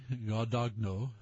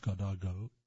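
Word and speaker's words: Guadagno: Mary Gary Gadagno, that's G-U-A-D-A-G-N-O, I assume it's pronounced Gadagno, had low hope of ever finding his parents' wedding rings.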